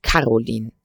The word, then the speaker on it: Caroline: Er teilte sein Dasein mit einer ebenso alten böhmischen Wirtschafterin namens Caroline, auf der ersten Silbe betont,